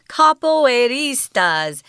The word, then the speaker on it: capoeiristas: and they're called capoeiristas. – Ooh, I hope I said that right. – You did. Good job. Yeah, it's not easy to say capoeiristas.